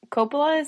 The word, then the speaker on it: Coppola: Oh, I'm drinking Francis Coppola.